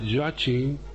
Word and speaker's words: Joachim: Yo esta mañana he hecho alguna prueba de sonido para ver cómo se pronuncia en alemán, y es Joachim, dicen Joachim.